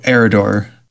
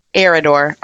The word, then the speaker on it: Arridor: How do you pronounce where she's from? – Oh, Arridor. – Arridor.